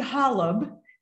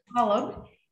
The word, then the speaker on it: Holub: It's Kathy Holub. – Holub. Got it. – Holub.